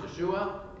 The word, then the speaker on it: Yeshua: That's how it would be pronounced, Yeshua, in Hebrew.